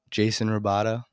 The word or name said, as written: Rebaudo